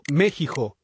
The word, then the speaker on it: México: Cristian Granados, pues quiso detenerse en el tema y revisar, pues, por qué México se escribe con X, pero se pronuncia con J.